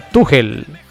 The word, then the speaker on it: Tuchel: Y como cultura general, se pronuncia Tuchel.